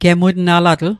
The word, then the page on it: gemutnalatl: Mi'gmaq